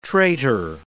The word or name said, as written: traitor